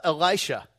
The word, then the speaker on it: Elisha: It's about Elisha. I'm pronouncing it E-L-I-S-H-A, not Elijah.